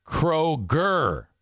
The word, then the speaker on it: Kroger: It's Kroher. It's pronounced Kroher. Ryan says undeserved abuse. Amanda says the songs give you douche chills. Kroger is how you say it.